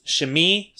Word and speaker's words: Shimei: I had to ask a friend of mine who knows Hebrew really well how to pronounce his name, because I didn't know if it was Shimei or Shimi, and she tells me it's Shimi, so I'm going to try to pronounce it right.